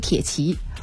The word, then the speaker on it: 铁骑: 两支球队都阔别世界杯赛场不短的时间，欧洲铁骑啊，现在念成欧洲铁骑。